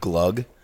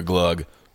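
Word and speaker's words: glögg: Well, it's spelled... – I would drink that. – It's spelled G-L-O-G-G. – Oh. – Doesn't make any sense. – So all these years, should we have been pronouncing that band T-R-O-G-G-S the Trugs? – I'm not doing this with you, Ben.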